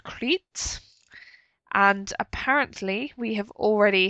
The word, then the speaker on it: Crait: And it's spelt C-R-A-I-T, so like that rather than like the Greek island.